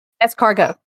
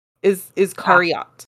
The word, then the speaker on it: Iscariot: Escargot. – No. Iscariot.